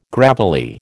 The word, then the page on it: gravelly: American English